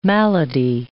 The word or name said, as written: malady